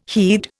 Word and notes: heed: Indian

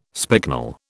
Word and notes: spicknel: British English

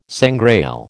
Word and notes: sangrail: American English

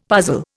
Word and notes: puzzle: Indian

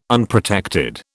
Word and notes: Unprotected: British English

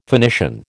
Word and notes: Phoenician: American English

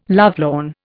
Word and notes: lovelorn: Indian

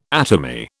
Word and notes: atomy: British English

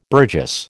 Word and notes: burgess: American English

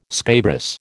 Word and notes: scabrous: American English

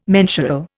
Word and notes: mensural: Indian

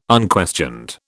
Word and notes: unquestioned: British English

